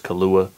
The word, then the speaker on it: Killua: But I've heard him say it on one track so far, so I know it's pronounced Killua. – Yeah. That's how they say it in English, but I don't watch that dub shit.